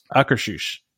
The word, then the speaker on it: Akershus: Akershus.